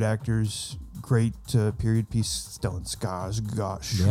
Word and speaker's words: Skarsgård: Skarsgård. Skarsgård.